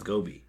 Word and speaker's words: Gobi: It's Gobi.